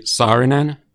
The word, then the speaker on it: Saarinen: So, like here, I say Saarinen.